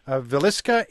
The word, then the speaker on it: Villisca: Stephanie with us from Villisca in Iowa.